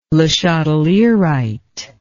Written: lechatelierite

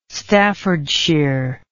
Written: staffordshire

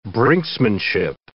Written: brinksmanship

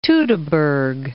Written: Teutoburg Forest

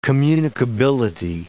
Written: communicability

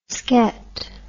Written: sket

